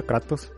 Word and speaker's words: Kratos: Hoy me di cuenta que sí se pronuncia Kratos porque es griego.